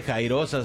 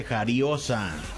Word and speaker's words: jariosa: No se dice jairosa, se dice jariosa.